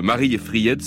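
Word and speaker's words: Mariefred: Une petite ville du nom de Marie et Fried, ça s'écrit Marie Fred, au bord duquel d'ailleurs se trouve un château aux 3 tours rouges, Gripsholm.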